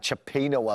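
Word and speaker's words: cioppino: Chopino, I believe, is the correct pronunciation.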